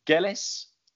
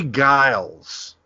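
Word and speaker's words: Geils: Is it Gellis? Jay Gellis Band? – Jay Giles. – Oh, okay. So neither, basically. Fantastic. Okay, yeah. Great. So yeah, Jay Giles Band.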